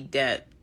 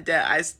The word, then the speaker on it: debt: She's saying Deb. Deb. She don't even know how to pronounce the whole word. – Deb. Like, I'm not in debt.